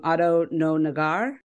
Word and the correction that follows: Oto no Nagare: Yeah, I say Oto no Nagare.